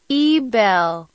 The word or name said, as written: Ebel